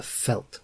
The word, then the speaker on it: Veldt: My Afrikaans-speaking friends tell me that it should be pronounced The Felt with a th.